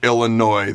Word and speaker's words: Illinois: Here are my three keys to a Husker victory this Saturday over Illinois. The S is silent.